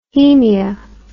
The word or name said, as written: hemia